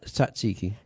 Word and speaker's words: tzatziki: and some tzatziki. Is that how you pronounce that? – What, the mushrooms? – No, the... – Tzatziki, is it? – Tzatziki.